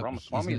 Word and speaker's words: Ramaswamy: Ramaswamy, did I say that right? – Uh-huh.